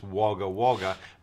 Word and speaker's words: Wagga Wagga: You know, and everyone knows it's pronounced Wagga Wagga, W-A-G-G-A, but to read it says Wagga Wagga.